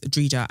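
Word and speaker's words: Dreda: Her name Drida is Irish and pronounced with a long vowel E sound in the middle, which is why I've been doing anyway.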